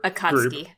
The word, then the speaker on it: Akatsuki: Yeah, so the Street Shark and Sasuke's bro say the number one priority of the Atatsuki, their Akatsuki. – Group, Akatsuki? – Yeah, it's not pronounced like it's spelled.